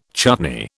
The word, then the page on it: chutney: British English